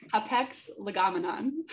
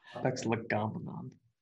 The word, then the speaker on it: hapax legomenon: How do you say it? Legomenon. – Hapax legomenon. – Hapax legomenon. Wow.